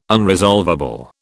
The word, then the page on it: unresolvable: British English